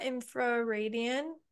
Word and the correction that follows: infradian: infradian. – Infradian, yeah.